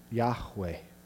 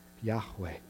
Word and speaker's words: Yahweh: It would most likely be pronounced Yahweh, Yahweh.